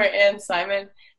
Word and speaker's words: Simon: Yeah, so it's not Anne Simon, it's Simon. But I prefer Anne Simon because especially here with like just the language, like my name wouldn't sound good.